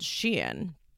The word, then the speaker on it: Shein: But I got it from Shein, or do some people call it sort of shine? I call it Shein. That's how it's spelled, Shein.